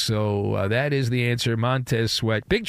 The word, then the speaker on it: Montez Sweat: Yeah, it's a guy you mispronounced earlier. It's Montez Sweat.